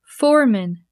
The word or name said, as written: foreman